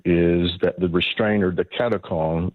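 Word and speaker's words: katechon: How do you pronounce this, and what is Katachan? – Katakon.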